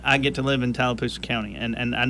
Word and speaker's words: Tallapoosa: In studio, I have from Tallapoosa County. Did I say that right? – Tallapoosa County. That's right.